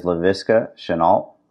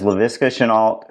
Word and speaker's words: Laviska Shenault: That is Laviska Shenault. Did I say it right? – Yeah. Yes, okay. You did. – That is Laviska Shenault, who has 21 catches for 388 yards and two touchdowns.